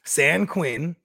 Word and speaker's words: San Quinn: So, okay, let's play some San Quinn, just like it's spelled, like the prison, right?